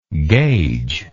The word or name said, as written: gaugebly